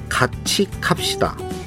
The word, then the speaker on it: 같이 갑시다: 참 발음이 어렵네요. 정확하게 하면 같이 갑시다입니다이 영화가 얼마 전 휴스턴 국제영화제에서 은상을 받았어요. 영화 같이 갑시다 조승원 감독 만나보겠습니다.